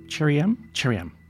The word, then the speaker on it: Cherym: Am I saying that right? Cherim. There we go.